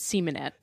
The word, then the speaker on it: Semenette: Yeah, so I pronounce it Semenet. – Semenet. All right. – Just so you know, either way, interpretation is fine.